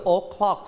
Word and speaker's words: o'clock: o'clock ใช่ไหมครับแต่ทิเชอร์อยากจะให้ลดเสียงลงหน่อยลดเสียงตัว o อ่ะไม่ต้องออกเสียง o เต็มๆ o'clock แบบนี้นะครับลองออกเสียงซิ o'clock